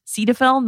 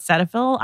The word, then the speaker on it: Cetaphil: First and foremost, that the brand is pronounced Cetaphil, not Cetaphil.